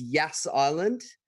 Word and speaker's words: Yas Island: It's a soft J. – Well, Yas? It's spelled with a Y, but we can spell it with a J if we'd like. – It's a hard Y. It's a hard Y or a soft J. – It's a very soft J. It's like Yas Queen.